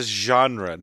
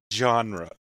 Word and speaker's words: genre: I just want to stress it's a soft G. It's a genre, not a genre.